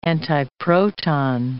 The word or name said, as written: antiproton